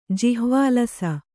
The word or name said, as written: ಜಿಹ್ವಾಲಸ